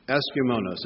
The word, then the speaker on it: euschemonos: Decently here is translated from the Greek word eskumenos. Eskumenos. It's spelled E-U-S-C-H-E-M-O-N-O-S.